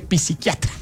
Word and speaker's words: psiquiatra: Es que no se dice psiquiatra. Qué pena con usted, pero es que la P no se pronuncia.